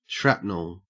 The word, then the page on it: shrapnel: Australian English